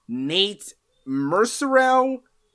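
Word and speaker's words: Nate Mercereau: Nate Mercerow, I'm told that's how it's pronounced according to Adult Swim promotional thing, so I got it wrong the first time we talked about that guy, apparently.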